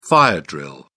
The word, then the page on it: fire drill: British English